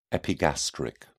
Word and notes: epigastric: British English

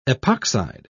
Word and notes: epoxide: British English

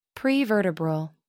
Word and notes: prevertebral: American English

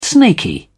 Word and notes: snaky: British English

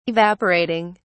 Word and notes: evaporating: British English

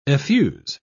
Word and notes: effuse: British English

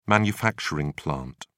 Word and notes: manufacturing plant: British English

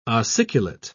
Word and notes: ossiculate: British English

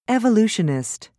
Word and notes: evolutionist: American English

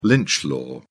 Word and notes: lynch law: British English

